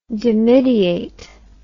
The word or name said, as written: dimidiate